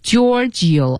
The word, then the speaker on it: Giorgio: Yeah G-I-O G-I-O G-I-O-R-G-I-O 对，它是 G-I-O-R-G-I-O. – Yeah, Giorgio. – Giorgio. – Yeah. Armani. A-R-M-A-N-I. Yeah. – Armani. – Armani. – Yeah. Armani. – Yeah. – Giorgio Armani.